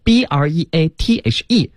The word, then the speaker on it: breathe: 对，所以大家一定注意哈，有 e 的时候 breathe 才是动词，它没有 e 呢，它只能读成 breath，它是一个名词儿。